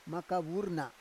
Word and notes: maka vur na: Roon

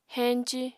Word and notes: henci: apatani

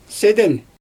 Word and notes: seden: Xyzyl

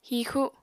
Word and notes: hixu: Apatani

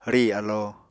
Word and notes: rì alo: Apatani

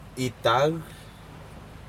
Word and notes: itac: Aneityum

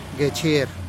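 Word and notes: gechier': Teotitlán del Valle Zapotec

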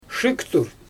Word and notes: ʃɯɣtur: Xyzyl

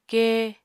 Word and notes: kee: Apatani

